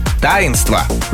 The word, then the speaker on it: таинство: Как нужно произносить? Кухонный, плодоносить, таинство, жерло. – Да-да-да. Внезапно. – Жерло и лассо.